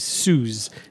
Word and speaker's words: Suze: Suze. – Oh, Suze. – Yeah, yes. – S-U-Z-E.